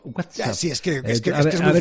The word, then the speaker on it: WhatsApp: ¿Tú eres de los que dice, WhatsApp? ¿O de esos otros que marcan la T WhatsApp, como dando a entender que ellos sí saben pronunciarlo y los demás no? – No, yo digo WhatsApp.